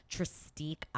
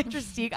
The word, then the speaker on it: Tristique: I especially use my Tristique eye pencils. I hope I'm saying that right, Alex. – It it's is correct. French, yes. right? – my Tristique eye pencils.